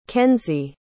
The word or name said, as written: kensey